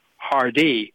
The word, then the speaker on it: Hardee: And she told him, we pronounce our name Hardee.